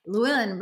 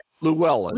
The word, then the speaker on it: Llewellyn: Whereas somebody like Desmond Llewellyn, I can't say his name, as Llewellyn. – Llewellyn, there we go.